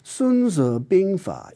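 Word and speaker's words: Sun Tzu Binghua (孫子兵法): Rather, Sun Tzu Binghua, its Chinese title, which I'm pronouncing in modern Mandarin, is more accurately translated as Sun, a surname, Tzu, the masters, Bing, military, and Fa, method.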